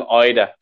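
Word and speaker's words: Idah: Eda, he doesn't like it being called Eda. He tweeted the other day saying Eda and laughing faces, so it's Adam Eda from now on for anyone watching.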